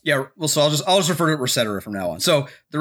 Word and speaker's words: ResetEra: The new site was called Reset Era, spelled Reset ERA. – Pronounced Resetera. – It's mostly pronounced Resetera as far as I can tell.